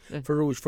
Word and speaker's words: Farooj: And the am I saying it right? It's Farooj? – Farooj. Farooj means chicken, like okay. – the chicken when it's like a whole chicken. – Yeah. – That's what means in Arabic.